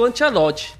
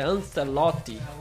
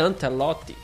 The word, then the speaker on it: Ancelotti: Eu digo Ancelotti, né? Mas o pessoal tá dizendo que não. É Ancelotti. Né? Ancelotti. Né? Mas, falando aqui na minha linguagem, ele se reuniu já com o técnico Ancelotti, já viu o restante do elenco.